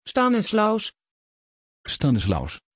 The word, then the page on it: Stanislaus: Dutch